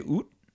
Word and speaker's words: Ut: It's spelled UT and it's a Cambodian name. – Oh, but it's like oak. – Yeah. In Vietnam, they say oot, but in Cambodia, it's pronounced oak. – Okay. – It's like between a T and a K. So we say oak.